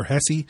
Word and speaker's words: Hesse: I do have Parker Hesse, and thanks to a loyal listener for upgrading my pronunciation game there on Parker Hesse, making the practice squad as the fifth tight end.